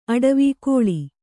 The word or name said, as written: ಅಟವಿ